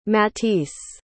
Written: matisse

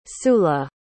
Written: sula

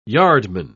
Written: yardman

